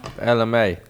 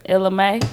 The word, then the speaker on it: Ella Mai: Ella Mai. – Ella May. – Ella May.